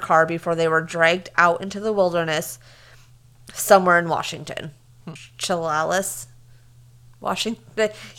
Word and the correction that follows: Chehalis: C-H-E-H-A-L-I-S. Chehalis? – C-H-E-H-A-L-I-S.